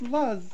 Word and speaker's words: Luz: Madeleine from Leicester was aged 3 when she vanished from a holiday apartment in Praia da Luz, in May of 2007. I don't know if I'm pronouncing that right or not. – It's Luz. – Yeah, it's more like a Praia Luz.